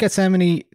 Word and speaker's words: Gethsemane: Ah, um, Dark Gethsemane, I believe it Gethsemane, is. Gethsemane. – Um, oh, like Yosemite. Okay.